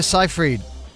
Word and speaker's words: Seyfried: Amanda Seyfried.